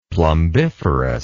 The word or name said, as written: plumbiferous